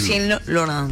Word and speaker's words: Lucien Laurent: Lucien Laurent.